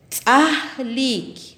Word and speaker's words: tzahlik: Banano en pocomá le decimos tzahlik. Tzahlik. Repitamos tzahlik.